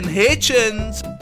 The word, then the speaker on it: Hitchens: Aiden Hitchens. That rhymes with kitchens.